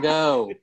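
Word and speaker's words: Fagot: The ignorant Fago. Won't know the difference. – Fago. The T is silent. – Are you sure? – It's French.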